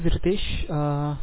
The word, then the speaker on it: Ritesh: Yeah, my name is Ritesh.